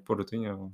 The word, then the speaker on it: Portuñol: How do you say it? no Spanish, Portuguese, Portuñol. – Portuñol.